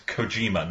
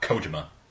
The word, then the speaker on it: Kojima: Yeah, and Shax, it's Kojima, not Kojima. – I've heard it pronounced both ways.